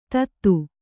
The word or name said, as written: ತತ್ತು